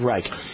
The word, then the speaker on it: Reich: How do you pronounce it? I don't even know. Third Reich, I believe. Third Reich.